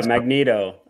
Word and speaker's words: Magneto: Yeah, Magneto.